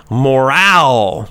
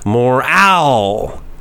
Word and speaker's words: morale: We are talking about morale, the second half of right the the second syllable, the second sound is said more strongly. Morale.